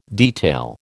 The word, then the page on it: detail: American English